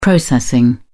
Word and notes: processing: British English

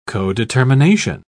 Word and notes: codetermination: British English

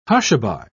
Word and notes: hushaby: British English

